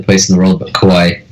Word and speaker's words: Kauai: Kauai. – Kauai, like Kauai Leonard for the San Antonio Spurs.